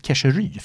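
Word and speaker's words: Keșerü: Men du vet, eh, han, han, ska ju egentligen uttalas Keshery för han är ungrare i, i, i, i stammen.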